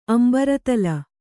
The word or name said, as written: ಅಂಬರತಲ